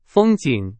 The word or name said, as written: 风景